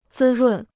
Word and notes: 滋润: zī rùn

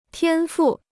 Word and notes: 天赋: tiān fù

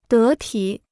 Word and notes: 得体: dé tǐ

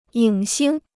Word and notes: 影星: yǐng xīng

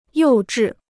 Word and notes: 幼稚: yòu zhì

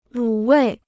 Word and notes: 卤味: lǔ wèi

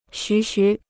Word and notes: 徐徐: xú xú

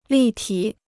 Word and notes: 立体: lì tǐ

